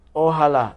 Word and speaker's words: Ojalá: Ojalá.